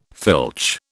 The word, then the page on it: filch: British English